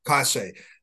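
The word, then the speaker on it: Cauce: President Anne-Marie Casse, am I pronouncing her last name correctly? – Casse.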